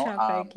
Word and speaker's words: Chapek: Chapek, yes.